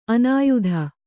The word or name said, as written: ಅನ್ಯಥಾ